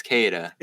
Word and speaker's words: Queta: Neemias Kada. It's Kada, not Queda, but it's spelled Queda. People loving Kada.